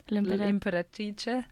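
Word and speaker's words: L'Impératrice: I think, was it Italian pronunciation, right? – L'imperatrice. Yeah, that's how I pronounced it.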